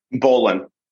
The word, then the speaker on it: Bowlin: Bowlin.